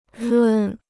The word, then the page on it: 润: rùn